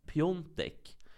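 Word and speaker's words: Piątek: Alltså att polackerna uttalar just det här, eh, när det stavas så, sån här då, Svia, tek, uh -huh. som ON. För eh, fotbollsspelaren Krzysztof Piontek ja.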